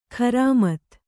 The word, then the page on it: ಖರಾಮತ್ತು: Kannada